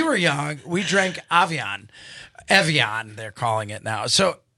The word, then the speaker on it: Evian: Anastasia, do you remember Avion? Have you seen Avion? – Evian. – Evian.